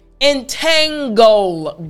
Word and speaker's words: entangle: Entangle, goal, that last part. It's the goal, goal, the last three letters. You see, it's the g sound.